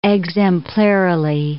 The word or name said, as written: exemplarily